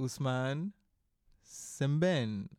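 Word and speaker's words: Ousmane Sembène: By Usman Simben, I believe. – Yes. – That's how his name is pronounced. – I believe so.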